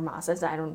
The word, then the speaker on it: Maas: Moss, like M-O-S-S? – No, it's M-A-A-S. – Okay. – Sarah J. Maas, or Mosses. Some people pronounce it differently.